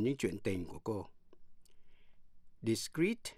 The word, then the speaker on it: discreet: Discrete, D-I-S-C-R-E-E-T là giữ kín. Và discrete, D-I-S-C-R-E-T-E là rời rạc. Phân tách. Phát âm như nhau, discrete.